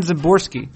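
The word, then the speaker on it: Szymborski: The progenitor of the Zips projection system, and the person for whom we might call Zips an eponymous projection system, is Dan Zimborski. The S is silent, you might say.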